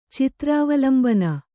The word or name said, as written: ಚಿತ್ರಾವಲಂಬನ